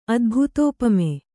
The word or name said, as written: ಅದ್ಭುತೋಪಮೆ